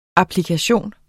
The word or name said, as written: applikation